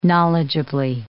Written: knowledgeably

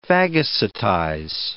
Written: phagocytize

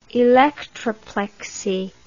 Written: electroplexy

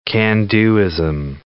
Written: can-do-ism

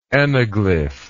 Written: anaglyphy

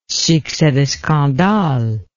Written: succes de scandale

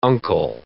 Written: uncle

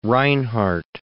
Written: Reinhardt

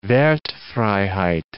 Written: Wertfreiheit

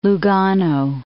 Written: Lugano

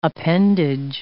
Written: appendage

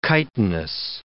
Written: chitinous

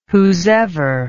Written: whosever

